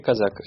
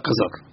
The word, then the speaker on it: Казак: Не-не-не, ударение «Казак». Это «Казак». – «Казак», да. Ударение ставится в этой фамилии на втором слоге.